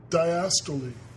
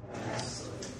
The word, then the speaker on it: diastole: Diastole. – Diastole.